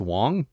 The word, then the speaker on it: Wang: Apparently it's spelled Wang, but it's pronounced Wong.